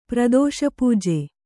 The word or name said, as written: ಪ್ರದೋಷಪೂಜೆ